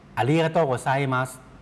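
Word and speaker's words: อา ริก าโตะโ ก ไ ซมั ส (ありがとうございます): อาริกาโตะโกไซมัส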